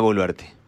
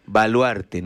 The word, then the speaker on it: Boluarte: Es más, su apellido seguía siendo mal pronunciado. Hasta ahora creo que todavía hay algunos que siguen diciendo Baluarte, ¿no? Dina Baluarte, dicen.